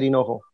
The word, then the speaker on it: Hinojo: And you can find me on YouTube, Twitter, Instagram, just my name, Javier Hinojo.